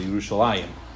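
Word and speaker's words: Yerushalayim (ירושלים): There's a chirik in between the lamed and the end of mem, because you're meant to pronounce it Yerushalayim, but the yud is missing.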